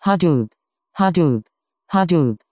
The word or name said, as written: hadoop